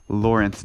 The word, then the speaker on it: Lawrence: My Instagram handle is Lawrence Dennis, my full name, and that is spelled L-A-W-R-E-N-C-E. And my last name is D-E-N-E-S.